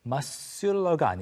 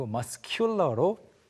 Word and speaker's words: muscular: 여기서는 U가 뒤에 따라오면서 살짝 스펠링이 바뀌어서 muscular가 아니고 muscular로 K 발음이 살아납니다.